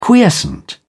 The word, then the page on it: quiescent: British English